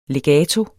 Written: legato

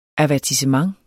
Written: avertissement

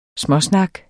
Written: småsnak

